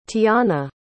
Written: tianna

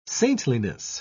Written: saintliness

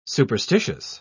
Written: superstitious

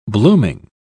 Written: blooming

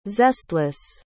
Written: zestless

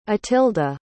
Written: atilda

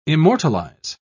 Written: immortalize

